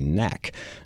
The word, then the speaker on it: NAC: If you're from the Nacogdoches area, you say NAC.